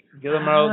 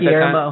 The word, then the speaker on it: Guillermo: No, it's about the movie by Guillermo del Toro. Did I say it right Guillermo. – That time? Guillermo. God damn it, I always say it wrong.